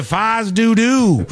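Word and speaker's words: fais do-do: I'm shocked you aren't familiar with the term Fies Du Du. – Or Fie Do Do. – Yeah. – From the Fies Du Du. – That's how they say it. The locals say Fies Du Du.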